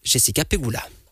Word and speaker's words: Jessica Pegula: Il s'agira de l'Espagnole Sara Sorribes Tormo ou l'Américaine Jessica Pegula.